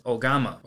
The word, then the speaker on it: ogama: Today's Lessons is going to be the Japanese word for scythe, and that is ogama. Ogama is spelled O-G-A-M-A